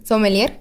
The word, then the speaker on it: sommelier: Sommelier. – Sommelier, se dice sommelier.